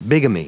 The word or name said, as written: bigamy